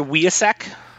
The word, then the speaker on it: Wiacek: How do you pronounce this inker's name? Bob Wiacek? – I've always heard Wiacek.